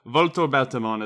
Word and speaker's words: Volto Bautamon: Yeah, Volto Bautamon is the correct pronunciation because Volto means mask, okay. And Bauta also means different kind of mask, so he's Mask-Mask-Mon.